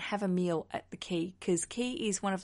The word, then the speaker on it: Quay: It's spelled Quay, the Quay restaurant, not the, just Quay.